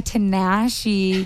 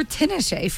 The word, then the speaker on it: Tinashe: 333 by Tinashe, because it rhymes with nasty. I literally just asked Lulia how to say it again. Because I called her Tinashe forever.